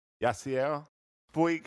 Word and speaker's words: Yasiel Puig: Do you think we're getting, you want to just jump right into, what is it, Yasiel? Puig.